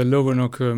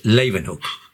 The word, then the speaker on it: Leeuwenhoek: And in the 80s, you rediscovered the power of the Leeuwenhoek microscope. I hope I pronounced his name right. – No, you didn't pronounce it right. It's Leeuwenhoek.